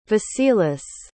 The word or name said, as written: vasilis